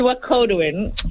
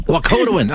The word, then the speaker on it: Wakatowin: It's Wakatowin. – Wakatowin. I had the emphasis on the wrong syllable.